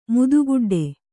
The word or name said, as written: ಮುದಿಗೊಡ್ಡು